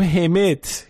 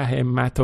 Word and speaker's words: همت: آقای مجتبی خاتمی هم یه کامنتی گذاشته بود در ادامه بحث این همت و همت، نوشته بود که اگه بخوایم درست این حروف اختصاری رو تلفظ بکنیم باید بگیم همت، کسره زیر ه و کسره زیر میم، نه همت و همت و اینا، که درست هم هست